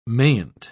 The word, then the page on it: mayn't: British English